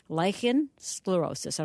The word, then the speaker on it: lichen sclerosis: Tell me about lichen sclerosis, L-I-C-H-E-N, lichen sclerosis.